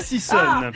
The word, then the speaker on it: sissonne: Ah voilà, vous l'avez mal prononcé. – Pas du tout. Je suis féru de classique et de ballet. Si je vous dis la sissonne, la sissonne, danse classique ou charcuterie.